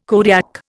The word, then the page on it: Kodiak: Indian